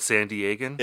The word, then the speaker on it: San Diegan: I don't think Marco's the first San Diegan. Is that how you say it? San Diegan? – It is.